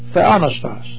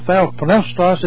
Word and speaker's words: theopneustos: That one Greek word is theonostos. Theonostos. Theos plus pneo. That God is the theos. Pneo is the verb to breathe. Theonostos or theopneustos, if you wish to pronounce every syllable.